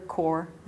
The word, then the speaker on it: Corps: That is Survivor Corps, and that is spelled C-O-R-P-S, like the French way, .org.